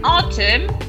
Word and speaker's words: autumn: No, cuidado con autumn porque. Pasa igual que con August, ¿vale? Se escribe A-U-T-U-M-N, ¿vale? Autumn, pero no hay ninguna A ni ninguna U, ¿vale? Es autumn.